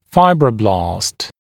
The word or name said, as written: fibroblast